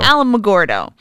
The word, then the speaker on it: Alamogordo: There's Alamogordo. I think I'm saying that right. A KOA. – Alamogordo.